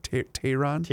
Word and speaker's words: Tehran: I always pronounce this incorrectly. Is it Tehran? – Tehran.